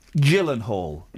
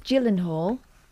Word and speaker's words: Gyllenhaal: Jake Gyllenhaal. – Yeah. – It's a soft G. Mm. It's like you don't pronounce the Moog synthesizer Moog. You pronounce it Kelly. – Mooge. – Moog. – Right. Jake Gyllenhaal.